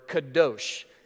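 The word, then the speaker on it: kadosh (קדוש): But just in case, I gave you the pronunciation below it. The Hebrew word on the screen is the word kadosh. Okay, that's a very satisfying word to say, kadosh, right?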